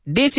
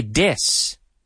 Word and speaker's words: this: But this is wonderful. But no, this, hay que sacar la lengua. To pronounce it properly, you have to stick your tongue out. Otherwise, it'll say dis. This.